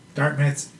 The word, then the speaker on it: Dartmouth: Dart, is it T silent or Dartmouth? – I mean, not that pronounced, but kind of Dartmouth. – Dart. – Dartmouth.